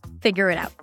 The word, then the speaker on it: figure it out: Figure it out as if it's one word.